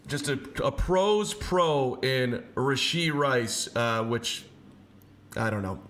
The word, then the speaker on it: Rashee Rice: Rashee Rice. – Rashee Rice, ladies and gentlemen.